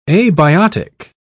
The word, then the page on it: abiotic: British English